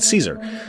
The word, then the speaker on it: Caesar: Everything east of the river belongs to Kaiser. I'm sorry, they would pronounce it Caesar.